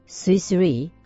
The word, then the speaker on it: Swiss Re: スイスリー？ – そうですね。スイスリーは、スイスに RE と書いてスイスリー。ブルースリーではありません。